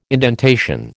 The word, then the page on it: indentation: American English